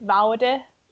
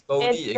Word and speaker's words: Vaude: So many would call it Vaude or Vaud, because they think it's French, because it's written like with a V-A-U-D-E. So yeah, many people don't know that it's actually a German-based company.